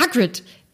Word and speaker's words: Hagrid: Und sie hat dann gesagt, nein, ähm, der heißt auch Hagrid, aber da steht Hagrid, ja oder Hagrid habe ich wahrscheinlich gesagt, ja, aber das wird wie ein Ä ausgesprochen. Das konnte ich nicht glauben.